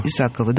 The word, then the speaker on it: Исакова: И Виктория Исакова. Я правильно ударение ставлю? – Исакова. – Исакова, да. Которая играет героиню, которую зовут Анна.